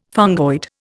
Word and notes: fungoid: Indian

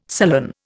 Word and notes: sullen: Indian

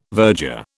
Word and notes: virger: British English

